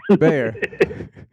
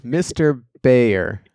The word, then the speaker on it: Beijer: Beijer. – How long have you known me and you still can't get it right? – I know. Mr. Beijer. The J is silent.